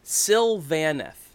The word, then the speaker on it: Sylvaneth: It is actually Sylvaneth. – Sylvaneth? – Sylvaneth. – The the emphasis on the van.